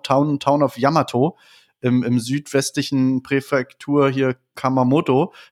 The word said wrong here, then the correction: Kumamoto: äh, Kuma, Kumamoto und äh,